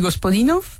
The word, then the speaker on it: Gospodinov: Georgi Gospodinov, sí.